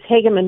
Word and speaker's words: Hageman: Is it Hageman, Harriet? – It is Hageman with a long A.